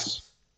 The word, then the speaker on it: Chiles: I thought it was Aiden Childs, but I could be wrong. – Okay, see, I was gonna pronounce it like the country, but I like Chiles. – Chiles. Yeah. – Chiles. – Yeah. So we're gonna go Chiles.